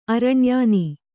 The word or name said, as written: ಅರಣ್ಯಾನಿ